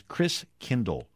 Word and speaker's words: Christkindl: And the German pronunciation of Christ child is Christkindl. Like kindergarten, kindercare, kinder means child, and Chris means Christ.